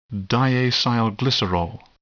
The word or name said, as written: diacylglycerol